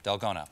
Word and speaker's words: dalgona: Now, one of the games you play in the show involves, and I'm sure I'm not pronouncing this correctly, dalgona, dalgona, yes.